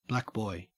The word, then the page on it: blackboy: Australian English